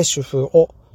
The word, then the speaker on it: 主夫男: で、えっ、ー、とね、主婦王さんなんですけども、えー、主、夫、男っていう感じでね、その 3 つの漢字で主婦をと読みます。